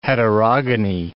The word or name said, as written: heterogony